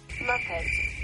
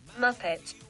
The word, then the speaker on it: Muppet: Muppet. – ¿Ves? – No, escúchala, este mira. – Muppet.